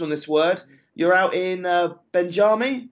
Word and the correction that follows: Bemidji: No, not quite. It's Bemidji. Bemidji.